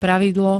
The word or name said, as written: pravidlo